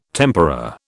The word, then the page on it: tempera: British English